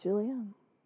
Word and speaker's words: julienne: Is it julienne? – Mm-hmm, julienne.